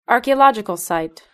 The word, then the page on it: archaeological site: British English